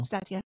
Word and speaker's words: Attia: I was reading Outlive by, I'm not going to say this right, Peter Attia? – Yeah, I think so. – Attia.